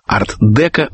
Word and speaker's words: арт-деко: Ну а теперь, как мы и обещали, поговорим о судьбе арт-деко в Америке. Здесь ударение переехало на первый слог.